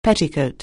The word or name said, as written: petticoat